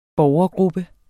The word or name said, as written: borgergruppe